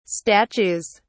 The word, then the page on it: statues: British English